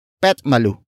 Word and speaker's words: petmalu: Malupit is spelled as M A L U P P-I-T, which literally means cruel or brutal. The syllables are mixed around to create Petmalu, with pit turning into pet, since Tagalog words that have I as the last vowel tend to be pronounced as e, e, like in malupet, malupet, malupet.